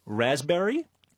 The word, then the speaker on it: raspberry: K-N-E-E-L, Neil. – Raspberry, answer. – Silent letters. – Yes, silent letters. – Oh, raspberry is? – The silent S. – P. – Well, P. Sorry. – Silent P, right.